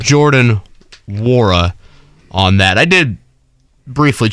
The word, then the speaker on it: Nwora: Wara. – Right. – Silent N. – Yep. – On that. So N-W-O-R-A, but the Louisville, they could do Louisville hat night for him.